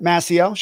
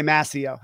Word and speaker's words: Mascio: Mascio, sure. – Okay, what's the actual pronunciation? – Oh, please? That's what I say, Mascio, yeah.